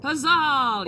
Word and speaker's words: Pezahl: Did I say it right? Is it Pezahl? – Pezahl, yep. Pezahl, okay. Yeah, I, am pretty, is that an Italian dish?